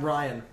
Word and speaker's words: Rian: Director Rian Johnson... no, his pronounce... – Ryan. – Ryan, but spelt stupidly.